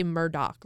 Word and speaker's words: Murdaugh: So it's Murdaugh, but they say Murdoch, like that's how they pronounce it.